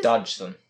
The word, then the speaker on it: Dodgson: Charles Lutwig Dodgson, I think it's Dodgson. – Dodgson? See, I never pronounce it right.